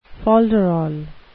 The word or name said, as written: folderol